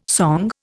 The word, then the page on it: song: Indian